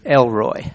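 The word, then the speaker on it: El Roi: Elroy. So if you can remember Elroy, I can remember Roy easy because that was Patsy's father's name, was Roy. This Roy is spelled R-O-I, and El means God, Roy means sees me.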